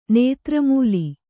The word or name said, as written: ನೇತ್ರಮೂಲಿ